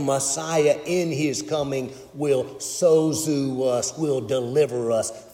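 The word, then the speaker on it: sozu (σῴζω): That word that's been interpreted in many of our translations of scripture in modern day, save, that word is pronounced sozu in Greek.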